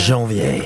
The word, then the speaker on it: Janvier: I think Janvier, do you want to pronounce it because you're better at French than me? – Janvier. – Yeah. – beautiful.